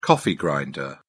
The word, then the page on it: coffee grinder: British English